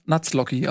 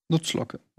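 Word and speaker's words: Nuzlocke: Spricht man das eigentlich Nutzlocke oder Nutzlocke Nutzlocki aus? Nutzlocke.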